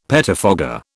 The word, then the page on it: pettifogger: British English